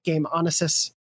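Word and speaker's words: GameOnassis: and at Game Onassis. Is that okay to pronounce it that way, Janet? – Yep. – I know people pronounce it differently, so I just wanted to check.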